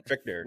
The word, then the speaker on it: Fichtner: Is this pronounced Fichtner? I've always said Fincher. – Fincher? – I thought it was Fichtner. – Fichtner. – Fichtner? Okay. Fichtner. – William Fichtner. – Corrected.